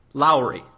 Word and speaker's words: Lowery: I'm going to spell a word, and I want you to tell me how you'd pronounce it. L-O-W. – Low. – Austin. L-O-W-E-R-Y. – I would pronounce it Lowry. – Lowry. Yeah. Okay.